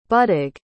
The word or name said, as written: buddug